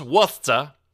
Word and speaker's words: Worcester: Pronounced Worcester, Massachusetts, and was annoyed to see someone flying a giant gay flag in that city.